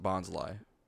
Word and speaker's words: Bonsly: They pronounce it Bonsly.